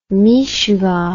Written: mishugah

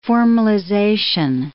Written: formalization

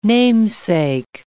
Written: namesake

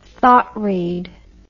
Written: thought read

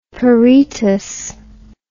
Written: peritus

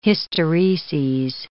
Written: hystereses

